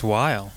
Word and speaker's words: Weil: I never asked you if I pronounced that right. – It's Weil. – Weil. Dan Weil. That's W-E-I-L for you dummies out there.